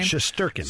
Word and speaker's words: Shesterkin: Shesterkin.